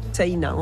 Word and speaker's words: Seina: Ça s'écrit un peu comme la Seine, mais... – Voilà, avec un A à la fin, Seina. On prononce le I aussi. – Comme si on avait un tréma au-dessus.